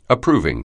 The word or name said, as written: approving